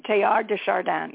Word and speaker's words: Teilhard de Chardin: Teilhard de Chardin, yes.